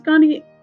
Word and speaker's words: gondii: I'm pretty sure it's pronounced gondii. I'm not a doctor, so I have no idea, but it's spelled G-O-N-D-I-I, gondii.